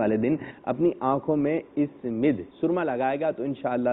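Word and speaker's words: اثمد: اس مد، یہ درست تلفظ ہے اس مد،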